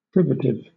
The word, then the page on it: privative: British English